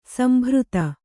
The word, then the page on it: ಸಂಭೃತ: sambhřta